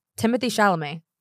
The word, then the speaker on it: Timothée Chalamet: Timothée Chalamet.